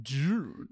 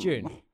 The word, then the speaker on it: Dune: Why do you say it like that? Dune. – How do you say it? – Dune. – Dune. It's not June. – It's June. – June, like June. The month of June.